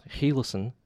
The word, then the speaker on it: Gielissen: Our parent company, Gielissen, which is in the Netherlands, is based in Eindhoven, is pronounced Gielissen, if I'm doing a decent job with my Dutch pronunciation.